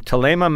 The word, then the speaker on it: Thelema: I think it's pronounced with a hard T, but it looks like Thelma with an extra E. So from Thelema Mountain Vineyards in Stellenbosch, the 2019 Chardonnay is a stunner, frankly.